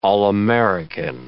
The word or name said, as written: all-American